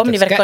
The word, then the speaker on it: Omniverk: Jaa, täiesti Ma ei tea, kuidas seda küll hääldatakse. – Omniverk ongi.